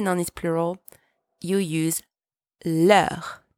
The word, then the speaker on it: leurs: There are two ways to say their in French leur and leurs. So yes, you heard that right. They do sound exactly the same, but one has a silent S and the other doesn't.